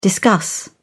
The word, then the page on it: discuss: British English